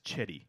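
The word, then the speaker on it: Cedi: The other one that pisses me off, he has now come out and said, no, the pronunciation is Chetty.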